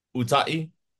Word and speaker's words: Utai: That's like the American way to say it. – So tell me the real name, the real way. – Yeah, the real way is Utai.